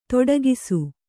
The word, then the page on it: ತೊಡಕಿಸು: toḍakisu